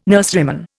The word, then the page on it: nurseryman: Indian